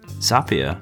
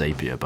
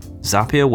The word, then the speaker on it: Zapier: Zapier, which is not pronounced Zapier, by the way, I made this mistake,